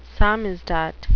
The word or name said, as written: samizdat